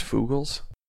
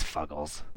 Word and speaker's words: Fuggles: They're using those real, like, Fugles hops or, you know, something that's really kind of... – Is it pronounced Fugles? – It's Fuggles.